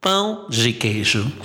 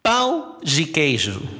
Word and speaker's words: pão de queijo: Se llama pão de queijo. Así como lo pronuncié con la nariz, pão de queijo.